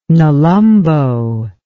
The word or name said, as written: nelumbo